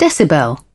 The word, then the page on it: decibel: British English